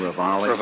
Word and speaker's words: Ravalli: Printz, the sheriff of Ravalli County, Montana. Is that the correct pronunciation, Ravalli? – Ravalli, yes, Your Honor.